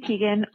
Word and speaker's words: Teigen: And I follow Chrissy Teigen on Instagram, and I just want to clarify, she said her name and pronounced her name on her stories the other day, and it is Teigen. But we've been saying it right this whole time. Chrissy Teigen, not Teigen, Teigen.